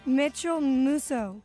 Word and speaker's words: Mitchell Musso: O sea, metieron, metieron a la cárcel a un tipo que se llama Micho Muso, o cómo se dice, Zenaida, Zaida. ¿Micho qué? – Mitchell Musso.